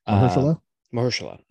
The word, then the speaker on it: Mahershala: And that's another one where I'm probably going to butcher the name, Mahershala Ali. – Mahershala.